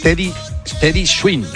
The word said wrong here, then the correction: Teddy Swims: Teddy Swims, y esta es una canción que hace con Tiago, la hemos puesto en algún otro.